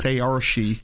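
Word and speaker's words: Saoirse: Say or she. – Ronan. It rhymes with inertia.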